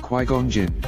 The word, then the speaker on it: Qui-Gon Jinn: Qui-Gon Jinn.